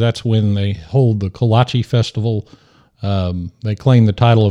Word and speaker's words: kolache: And I can tell you, if you eat kolaches in Caldwell, of course, I understand for all my Czech friends listening, it's pronounced Kolache, that you will have a good time.